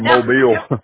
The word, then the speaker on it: Mobile: I always say Mobile, but I'm told it's oh, Mobile. Yeah, Mobile.